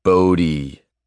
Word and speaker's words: Bodhi: "Bodhi," I corrected for the 100th time. "The H is silent, asshole."